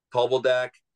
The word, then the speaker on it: Talbaldak: Like, and again, I'm not denying that, like, here, the one that, and say his name, Talbaldak, how do you say it? – Yeah, Talbaldak. – Talbaldak.